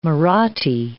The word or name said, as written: Marathi